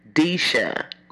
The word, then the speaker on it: Deisha: She was like, it's Deisha. Like Keisha, but with a D, Deisha.